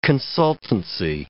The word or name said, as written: consultancy